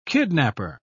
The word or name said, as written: kidnapper